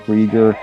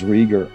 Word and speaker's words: Rieger: Joerg Rieger. Sometimes I have to sort of just spell it. First name is Joerg, and then the last name is Rieger, Rieger, and the book is Theology in the Capitalocene.